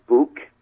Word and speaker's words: Buhk: B-U-H-K. – I get Bunk, believe it or not. – Okay. – Buck. Buke. Right. So, yeah, there's a lot of different variations of it, yeah.